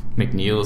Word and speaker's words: McNeil: Alex is spelled A-L-E-X. McNeil is spelled M-C-N-E-I-L.